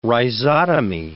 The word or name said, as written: rhizotomy